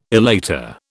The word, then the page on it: elater: British English